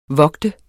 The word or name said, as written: vogte